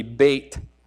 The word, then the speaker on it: Beit: You'll see in verse 9, above it says the word Beth. In Hebrew, it would be Beit. That's how you pronounce that. And every one starts with that letter that has the B sound in Hebrew.